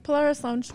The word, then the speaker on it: Polaris Lounge: Hopefully you see us in, we're in the I don't know how to pronounce. – Yeah, Polaris Lounge. – Polaris Lounge.